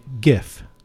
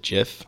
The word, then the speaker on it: GIF: I've always pronounced it GIF. It's like a giraffe, you know? – Right. – And I've gotten into small arguments with people over the years about, no, it's GIF, you know? It's a hard G.